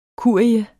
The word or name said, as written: kurie